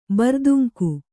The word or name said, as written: ಬರ್ದುಂಕು